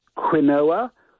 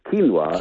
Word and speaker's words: quinoa: One that we all mispronounced when it first appeared, quinoa. Of course, it's quinoa. Now we know, but a lot of people are doing quinoa.